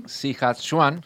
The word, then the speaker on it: Sichuan: La ciudad de, vamos a ver si lo digo bien, Sichuan.